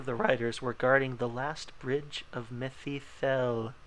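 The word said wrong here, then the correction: Mitheithel: I might have pronounced that right. Who knows? – Mithathel.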